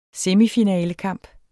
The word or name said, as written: semifinalekampen